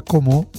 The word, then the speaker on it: Como: è di Como. E quindi sì. – Con la O stretta. – Con la O stretta, perché lì, sai, parlano così, sono di Como. Senza l'accento finale, semplicemente sarebbe Como.